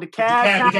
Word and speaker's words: DeKalb: Holding it down for Atlanta, DeKalb. I hope I pronounced that right. DeKalb, come on, DeKalb. – I'm sorry, DeKalb.